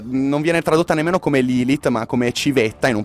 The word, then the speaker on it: Lilith: Lilith, appunto di cui stavamo parlando. La T si pronuncia, ok, basta che... – Sì, è, è, ebraico, non è, non è, inglese, diciamo così.